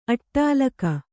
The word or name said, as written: ಅಟ್ಟಾಲಕ